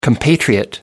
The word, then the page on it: compatriot: American English